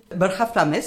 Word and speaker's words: Borja Flames: Borja Flames, si, se dice así, porque es español.